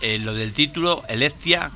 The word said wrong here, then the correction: Eclectia: que yo no sé si lo digo bien o lo digo mal, o lo habéis inventado, lo habéis buscado, es latino. – Eclectia, sí, Eclectia viene del latín,